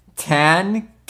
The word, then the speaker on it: Tanqueray: Tanqueray.